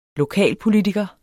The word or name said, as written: lokalpolitiker